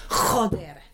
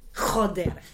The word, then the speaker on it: joder: Ich habe jedenfalls gedacht, wenn man Scheiße sagt oder wenn alles blöd ist, sagt man immer Joder. Joder. So, das habe ich immerhin gelernt. – Klingt wie Roger.